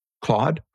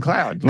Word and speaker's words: Claude: Did you pronounce it correctly just now? – Claude. – You had been saying cloud.